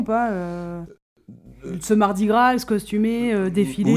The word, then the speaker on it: Mardi Gras: Ou Mardi Gras, mais ils le disent exactement comme tu l'as prononcé. – Mardi Gras. – C'est exactement ça, Mardi Gras.